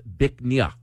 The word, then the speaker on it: Bich Nga: It's spelled B-I-C-H-N-G-A. It's pronounced Bic Nha, which loosely translates to beautiful jade.